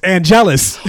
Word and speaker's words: Angelus: Angelus, and you said, you even said his name wrong. It's Angelus.